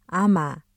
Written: 아마

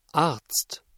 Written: Arzt

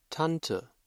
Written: Tante

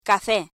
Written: café

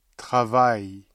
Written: travail

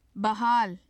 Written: bahaal

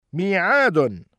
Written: مِيعَادٌ

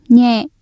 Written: nhẹ

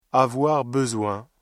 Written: avoir besoin

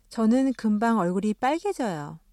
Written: 금방

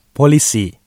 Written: polisi

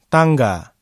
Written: tangga

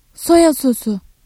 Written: soya sosu

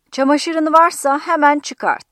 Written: hemen

